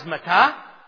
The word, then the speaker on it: matteh: And bed in Hebrew, if we were to transliterate it, take the letters over in English, it would be M-T-H. And staff is matah, and bed is matah. Staff is M-A-T-T-E-H, and bed is M-I-T-T-A-H. Staff is M-A-T-T-E-H. And bed is M-I-T-T-A-H. Mata, Mata.